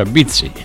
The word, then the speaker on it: Bitti: Che, che, poi in sardo si pronuncia Bizzi.